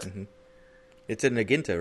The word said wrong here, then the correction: Naginata: You mean Naginata? – Or that too.